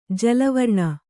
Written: ಜಲವರ್ಣ

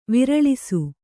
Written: ವಿರಳಿಸು

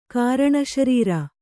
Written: ಕಾರಣಶರೀರ

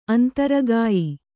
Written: ಅಂತರಗಾಯಿ

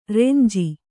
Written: ರಂಜೆ

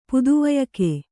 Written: ಪುದುವಯಕೆ

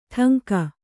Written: ಠಂಕ

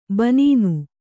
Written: ಬನೀನು

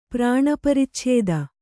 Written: ಪ್ರಾಣಪರಿಚ್ಛೇದ

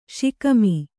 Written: ಷಿಕಮಿ

